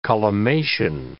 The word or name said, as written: collimation